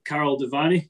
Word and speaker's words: Karol Devaney: Today we've got Karol Devaney.